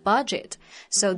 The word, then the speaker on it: budget: 嗯哼，那 reader 帮你推荐这个房子啊，他很多时候会考虑到你的这个预算 budget，so they may ask you, Do you have an idea of your budget？ – budget 就是刚刚说到的预算，我们要特别注意这个单词的发音啊，它的拼写是 b u d g e t，中间它虽然有一个 d，但是这个 d 它是不发音的，所以不要读成了 budget。